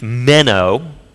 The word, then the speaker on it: meno: In Greek, it's meno, not a little fish. It's just, that's how you say it.